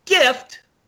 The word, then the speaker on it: GFYT: And my second horse would be pronounced Gift, spelled G-F-Y-T. – Okay. – Say it again. Wait, G what? – G-F-Y-T.